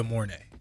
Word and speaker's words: De'Mornay: How are you supposed to say it? – No. Demone.